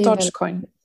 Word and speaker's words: Dogecoin: Men det här Dogcoin då? – Ja, så Do Dogecoin startade... – Dogecoin. Ja, det, var... – Eh, det är i alla fall så jag uttalar, ja.